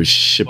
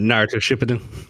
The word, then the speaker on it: Shippuden: How do you say that? Naruto Shippuden. – What, the next one? Shippuden. Naruto Shippuden.